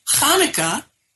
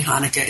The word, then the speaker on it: Hanukkah: Of course, we've all seen C-H-A-N-U-K-A-H, various permutations of that with the C-H at the beginning. But if you don't already know that that really means ch, you might end up pronouncing it like the ch in chair, which is not the way Hanukkah is pronounced either.